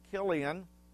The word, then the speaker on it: Chilion: Famine in the land, journeying to Moab, marriage, the death of Elimelech and Mahlon and Chilion. And Bonnie, you got those dead on right, the pronunciation.